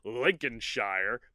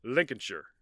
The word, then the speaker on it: Lincolnshire: I was going to call, because it's spelled Lincolnshire, but I know now that it's more like Lincolnshire.